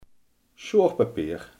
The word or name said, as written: schuurpapier